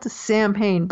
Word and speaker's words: Samhain: But it's not spelled anything like it sounds. It's spelled S-A-M-H-A-I-N, spelled Samhain, but it's pronounced Samhain.